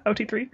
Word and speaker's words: OT3: OT3? Is that how you pronounce that in real life? – That's how I would say it, yeah. OT3.